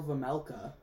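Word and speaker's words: Vejmelka: Well, then they sign, who knows who he is, Karel Vemelka, I think is how you say his last name. Yeah, the J is silent.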